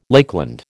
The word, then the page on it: Lakeland: American English